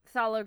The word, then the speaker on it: phthalo: That is phthalo green.